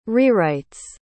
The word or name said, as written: rewrites